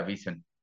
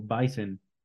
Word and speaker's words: Bisen: Estaba pensando otra pronunciación diferente y estaba hilándola con otra, estaba hilándola como Bison, no Bison. Ah, sí, no. – Si, si la E y la I estuvieran al revés, sí sería Bison.